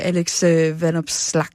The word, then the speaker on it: Alex Vanopslagh: Hvad tror du, Alex Vanopslagh? Ej, undskyld nu. Udtaler jeg det egentlig rigtigt? – Jo, det er ganske umærkt. Det gør det faktisk, ja.